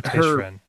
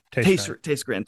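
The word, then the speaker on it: Tayschrenn: Oh, Taystren. – Taystren, thank you.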